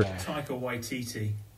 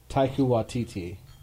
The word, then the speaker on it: Taika Waititi: Taika Waititi. – Taika Waititi. – Taika Waititi. – Waititi. – Yeah, the Kiwi. – Yeah, the Kiwi. I love how it has a pronunciation guide. – Taika Waititi.